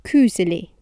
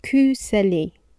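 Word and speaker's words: koselig: Kuzeli. Kuzeli. That's the Stavanger dialect pronunciation.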